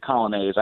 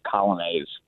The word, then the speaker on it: Coglianese: Well, the family says it Coglianese. I know we say it incorrectly, but we've all sort of just said Coglianese throughout the years. So that's the one we go by.